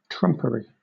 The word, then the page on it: trumpery: British English